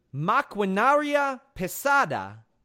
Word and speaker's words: maquinaria pesada: Maquinaria Pesada.